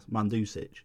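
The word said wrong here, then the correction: Mandžukić: Couldn't say his name last week. – Oh, Mandzukic.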